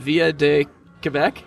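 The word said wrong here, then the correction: Ville de Québec: Ville de Quebec, yeah.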